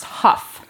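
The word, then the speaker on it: Hough: Laura said that her last name is actually pronounced Huff. – That girl looks like Meg Ryan. – Because that makes sense. – H-O-U-G.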